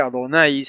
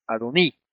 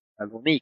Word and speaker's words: Adoní: Por lo tanto, debe ponerse una I en vez de una AI, y no decir Adonai, sino Adoní. Adoní, con acento en la I, ¿sabes?